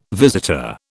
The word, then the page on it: visitor: British English